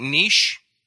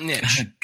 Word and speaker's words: niche: On this program, we pronounce it niche, not niche. – Got it. All right. – And it's not because we're pretentious. It's just because that's right, man.